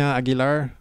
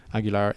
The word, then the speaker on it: Aguilar: Sebastian Aguilar. Spelling would be long, but S-E-B-A-S-T-I-E-N. That's French spelling. – Sure. – Aguilar, A-G-U-I-L-A-R.